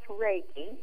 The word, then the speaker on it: Reiki: Yeah, it's pronounced Reiki.